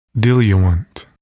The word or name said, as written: diluent